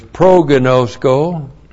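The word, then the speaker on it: Prognosko (προγινώσκω): And then there is prognosko. Prognosko, P-R-O-G-I-N, long O-S-K, long O.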